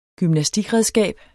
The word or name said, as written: GYMNASTIKREDSKAB